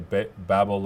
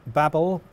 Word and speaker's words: Babel: And that's why I'm seeing the Babel loader here, because that's what compiles a TSX. Is that right? – Yeah. So Babel, or Babel, depending on how you like to pronounce it, is very widely used in the React world.